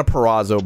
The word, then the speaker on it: Purrazzo: Pizarro. – Anyway, Deonna Purrazzo, by the way, is how you say that name.